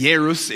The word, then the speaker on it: Jairus: And we're told that a man named Jairus, however you want to say it, Jairus is the pronunciation of the Greek, so go with it however you want to do it.